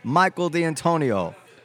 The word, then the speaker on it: Michael D'Antonio: So we've been joined by, Stephen wrote this one, Pulitzer Prize winning writer of books, articles, and original stories for film, Michael D'Antonio.